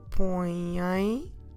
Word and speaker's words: põem: Vocês põem, keep your mouth open, don't forget, põem as luvas. Vocês põem as luvas. Vocês põem as luvas. Here we have põem, ending with this nasal vowel sound.